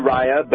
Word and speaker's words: Raya: Okay, and then also on DVD and streaming, we're talking, is it Raya and the Last Dragon? Is that... – It's actually Raya, but Raya, okay. – It looks like Raya, the way it's spelled.